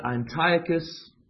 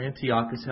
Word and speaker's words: Antiochus: Antiochus, or Antiochus, however you want to pronounce it, Epiphanes.